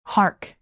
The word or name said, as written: hark